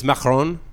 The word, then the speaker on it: macaron: But macaroons are different. – It's different. It's macaron, you know.